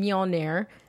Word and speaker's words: Mjolnir: Our Thanos head is on Mjolnir.